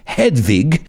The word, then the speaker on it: Hedwig: Her real name is Hedwig, which, if you want to pronounce it correctly, it's Hedwig.